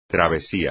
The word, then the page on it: travesía: espagnol